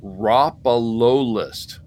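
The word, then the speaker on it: Rhopalolith: It is pronounced Ropalolist. That's how Space Mom says it.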